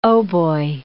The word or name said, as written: hautboy